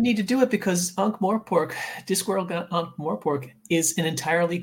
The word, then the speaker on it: Ankh-Morpork: They're selling Discworld Ankh-Morp. Ankh-Morp? Is that how you say it? – Ankh-Morpork. – Ankh-Morpork.